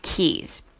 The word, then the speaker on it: cays: Now, it is spelled C-A-Y-S, but pronounced Keys.